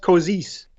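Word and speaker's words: Košice: Oh, it's I believe it's pronounced Kozice. – Okay, thank you. An accurate pronunciation from a real professional.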